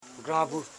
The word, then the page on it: granbu: gran.bu